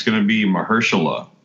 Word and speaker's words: Mahershala: Say it with me. Say Mur. – Hersh. – La. – Hershey's Kisses. – Mahershala.